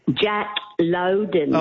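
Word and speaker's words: Jack Lowden: I just want to tell you that actor's name is pronounced Jack Loudon.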